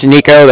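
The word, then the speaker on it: Niko: My name's Nico. That's N-I-K-O. And we're with the Bank of Cardiff. And that's spelled C-A-R-D-I-F-F as in Frank.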